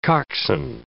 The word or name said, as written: coxswain